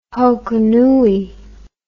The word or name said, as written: Hokonui